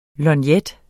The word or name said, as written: lorgnet